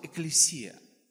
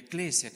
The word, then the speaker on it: экклесия: Греческое слово «экклесия» или «экклесия», как ее можно по-разному ударение ставить, состоит вот из этих двух интересных слов.